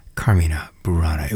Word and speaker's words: Carmina Burana: Carmina being the plural of Carmen, Latin noun for song, hence the first syllable stress, Carmina Burana.